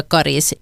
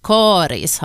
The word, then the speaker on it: Karis: Rekommendationen om långt av Karis har funnits sedan början av 1960-talet, och för det så fanns det faktiskt inte uttalsrekommendationer överhuvudtaget. Samtidigt är uttalet med korta troligen äldre, ofta är det talspråkliga uttalet äldre än det officiella.